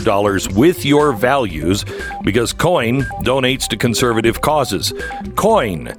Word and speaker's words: Coign: It is spelled COIGN, like sovereign is spelled, but it's coin. Coin. COIGN. It's the only conservative credit card.